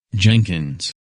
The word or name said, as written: Jenkins